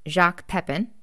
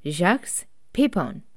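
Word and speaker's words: Jacques Pépin: Now, I would have pronounced it Jacques Pepin, but the nice French lady from Google says that it's Jacques Pepin, and so I'm going with that.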